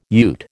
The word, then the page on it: ute: American English